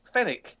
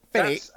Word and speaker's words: Fenwick: it should just be called Fenwick. – Fenwick. – That's a very English name, Fenwick, and it doesn't pronounce the W.